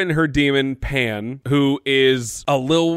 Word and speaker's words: daemons: Lyra's demon, which, by the way, for the season one, I kept calling them daemons because there's an A in the word. – Mm-hmm. And the world has been like, hey, it, the A is silent.